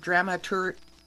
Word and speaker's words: dramaturge: stage director, dramatic coach, and the last word, dramaturge.